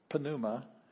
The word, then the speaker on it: pneuma: Pneuma in Greek, yeah. But we don't pronounce the P, but it's still got one on the front of it.